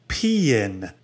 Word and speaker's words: paean: He said, he wrote in and he said, paean, which we're about to find out, is from the Greek and so is pronounced paean. So there you go. It's peeing.